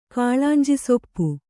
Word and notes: ಕಾಳಾಂಜಿಸೊಪ್ಪು: Kannada

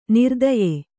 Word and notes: ನೃತ್ಯ: nřtya

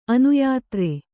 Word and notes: ಅನ್ಯದಾರ: anyadāra

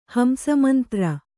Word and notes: ಹಂಸಮಂತ್ರ: kannada

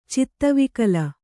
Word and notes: ಚಿತ್ತವಿಕಲ: Kannada

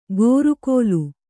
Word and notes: ಗೋರುಕೋಲು: gōru kōlu

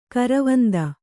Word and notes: ಗರ್ವಾಂಧ: garvāndha

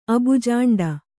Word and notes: ಅಬುಜಾಂಡ: kannada

